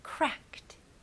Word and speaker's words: cracked: 여기서 ed를 붙일 때는 바로 되게 가벼운 t 발음만 붙이시면 돼요. 그래서 cracked, cracked. Very good.